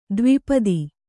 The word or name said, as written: ದ್ವಿಪದ